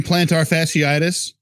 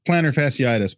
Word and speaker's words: plantar fasciitis: Because the way he kept saying plantar fasciitis, which who pronounces it that way? It's plantar fasciitis.